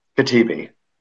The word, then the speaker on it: Katebi: Katebi.